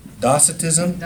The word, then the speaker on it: docetism: Docetism. – That'd be more accurate because the Greek word is kappa, pronounced as a K.